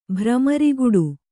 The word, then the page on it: ಭ್ರಮರಿಗುಡು: kannada